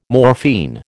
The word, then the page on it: morphine: American English